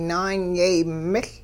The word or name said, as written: naanyay-miL